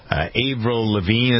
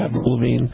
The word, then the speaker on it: Avril Lavigne: Avril Lavigne, that's how you pronounce her name, right? Avril Lavigne? – Avril Lavigne, yeah.